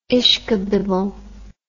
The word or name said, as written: ishkabibble